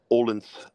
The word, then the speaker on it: Odense: It looks like Odense, but like Odense is how they say it.